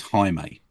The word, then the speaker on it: Jaime: It's spelt Jamie. His name's Jaime because he's Mexican. – Right. – So if you said Jamie, and somebody might, some pain in the ass creator might go, hang on a minute, I believe it's Jaime, you know, that sort of thing.